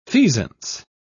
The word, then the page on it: feasance: British English